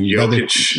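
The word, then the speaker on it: Jokić: Talk about uh, the twins versus the um, uh, the uh, Jokic or Jokic, whatever you say his name. – Jokic. Brother. Yeah, yeah, yeah, yeah.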